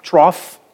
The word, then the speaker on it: trough: It's G-H, right, in the spelling, but it's trough.